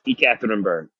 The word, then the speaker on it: Ekaterinburg: So, Coach, how do we even pronounce it? Is it ECAT? – Yeah, so that's where they shorten it up and say ECAT, but it's Ekaterinburg.